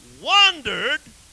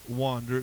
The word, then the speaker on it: wonder: It got enough power that the whole world wondered after this beast. Now, I'm pronouncing that word, but you're not reading it. What does that word say? It doesn't say wander, it says wonder. Spelled with an O.